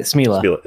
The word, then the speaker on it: Smela: Smela.